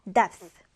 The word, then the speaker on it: depth: TH 발음 주의해서 depth, depth인데요.